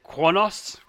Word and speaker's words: Kronos: I just wanted to say one of the things I liked in that a lot was in the past, the Klingon homeworld had always been called Kronos. And then in the new series, they pronounce it Kronos, right? In like real Klingon.